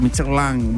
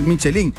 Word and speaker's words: Michelin: es Michelin, se pronuncia Michelin. Bueno, yo no sé exactamente cuál es la pronunciación, pero me regañaron 10,000 veces por decir Michelin.